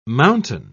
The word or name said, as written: mountain